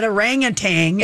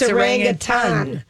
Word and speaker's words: orangutan: and she came in and she told us it was not orangutan, it's orangutan. – Thank you. – That's how you pronounce it.